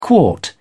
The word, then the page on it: quart: British English